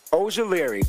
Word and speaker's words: Ojulari: Aziz Ojulari.